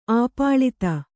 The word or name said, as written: ಆಪ್ಲುತ